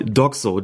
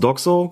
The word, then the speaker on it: DOGSO: Doxo. D-O-G-S-O. Das spricht man dann als Wort aus Doxo, genau wie man ja nicht F-I-F-A sagt, sondern FIFA.